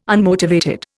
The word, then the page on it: unmotivated: Indian